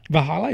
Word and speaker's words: Valhalla: Valhalla is V-A-L. – Valhalla. – Valhalla, is that how you pronounce it? – Yeah.